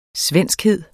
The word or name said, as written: svenskhed